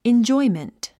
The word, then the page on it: enjoyment: indʒɔ́imənt; インジョイメント